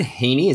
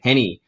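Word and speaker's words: Henne: But is it Chad Heaney? Is that how you pronounce it? Or Chad Henney? – Henney.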